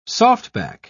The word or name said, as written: softback